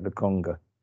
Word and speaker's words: Lokonga: Is that how you say it, Lokonga? – Yeah, it's phonetic.